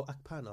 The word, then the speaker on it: Akpan: Akpan? Is that how it pronounced right? – Is that true about Akpan? Is it spelled A-K-P-O-M? – P-A-N. – Oh, Akpan, I've never heard of it.